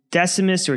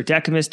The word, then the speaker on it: Decimus: It was founded as a Roman colony back in the year 138 BCE by the consul Decimus, or Decimus, depending on your pronunciation, Junius Brutus Callicus.